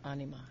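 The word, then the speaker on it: Ænima: Anima? – Anima.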